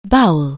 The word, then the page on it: bowel: anglais